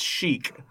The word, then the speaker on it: Sheik: Oh, I think it's Sheik. – See, I keep on pronouncing it like the title, Shake, like if you were Shake Mohammed. But maybe it is Sheik. – I think. Because when you play Smash Brothers, it yells Sheik at you.